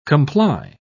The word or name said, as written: comply